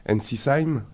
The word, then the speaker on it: Ensisheim: On s'est vu hier soir au CAF Cons à Ensisheim, je l'ai bien prononcé ? – Très bien.